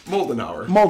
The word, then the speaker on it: Moldenhauer: Moldenhauer. – Much like the malting hour.